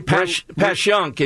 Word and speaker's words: Passyunk: Okay, Passyunk is the correct pronunciation in South Philly.